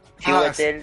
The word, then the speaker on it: Chiwetel: Chiwetel ah, sí.